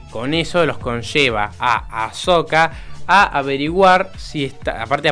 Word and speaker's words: Ahsoka: En Ashoka, ah, que no se dice Ayoka, se dice Ahsoka, pero yo todavía le dije Ashoka.